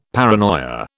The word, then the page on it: paranoia: British English